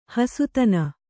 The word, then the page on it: ಹಸುತನ: hasutana